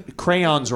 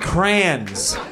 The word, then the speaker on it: crayons: Oh, people who say crayons wrong. He just said crayons. What the frick? Anytime I hear that, I'm like, what? It's crayons, isn't it? – You do two syllables? – Yeah.